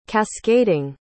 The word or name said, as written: cascading